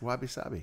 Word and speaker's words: wabi sabi: and I'm sure I'm not pronouncing that right. – I think you are actually. – Okay. It's wabi sabi.